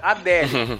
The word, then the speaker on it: Adele: E, se, e todo mundo sabe que se pronuncia Adele.